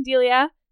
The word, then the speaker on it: Delia: It's Delia. That's how it's spelled. – Wait, D-E-L-I-A. Delia. – Delia. – Delia or Delia. I think it's Delia.